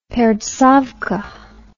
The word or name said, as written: pertsovka